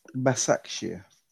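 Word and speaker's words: Başakşehir: It's pronounced Basakşehir.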